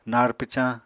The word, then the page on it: nar pwcañ: Apatani